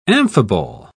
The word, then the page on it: amphibole: British English